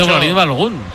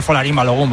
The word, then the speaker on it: Folarin Balogun: Dice Folarin Balogun. – Tiene que ser Folarin Balogun, vamos.